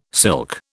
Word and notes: silk: British English

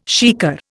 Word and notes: shikar: Indian

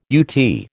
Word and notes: UT: American English

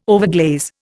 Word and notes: overglaze: Indian

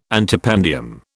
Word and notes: antependium: British English

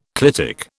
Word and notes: clitic: British English

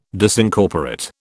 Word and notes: Disincorporate: British English

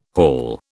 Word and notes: hall: British English

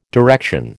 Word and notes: direction: American English

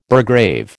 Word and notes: burgrave: American English

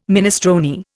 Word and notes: minestrone: Indian

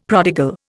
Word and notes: prodigal: Indian

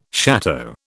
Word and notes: château: British English

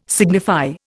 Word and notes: signify: Indian

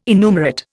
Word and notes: innumerate: Indian

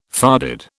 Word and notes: farded: British English

